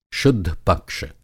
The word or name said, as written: शुद्धपक्ष